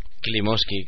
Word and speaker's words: Klimovsky: León Klimovsky, o como se pronuncia aquello. – Klimovsky, quiero decir.